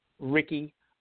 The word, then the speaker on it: Rickie: You can definitely follow me at my IG Instagram, and that is Rickie Recardo Photography. And Recardo is spelled R-E-C-A-R-D-O, Rickie, R-I-C-K-I-E.